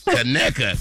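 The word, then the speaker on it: Conecuh: Kaneka. Is that how you say it? – It's from Kaneka County, Alabama, where my family is from. – Yes, Kaneka. Kaneka. That's Kaneka sausage.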